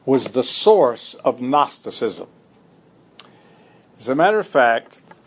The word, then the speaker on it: Gnosticism: G-N-O-S-T-I-C-I-S-M. Gnosticism. G-N-O-S-T-I-C-I-S-M. – Knowledge. – But it's pronounced Gnosticism.